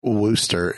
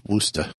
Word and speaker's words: Worcester: It's not Worcester, it's Worcester. – Actually, it's Worcester.